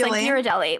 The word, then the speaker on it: Ghislaine: It's Ghislaine? Like Ghirardelli, okay. Chocolate. Like that's the Ghislaine. G-H. Yeah.